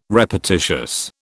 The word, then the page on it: repetitious: British English